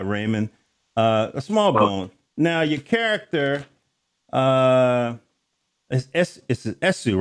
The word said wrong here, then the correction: Asao: You pronounce it Asao.